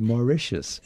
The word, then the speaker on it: Mauritius: So the next play at Main Stage West, which opens on September 27th and plays through the 13th, is, as you told me, is pronounced Mauritius. – Correct.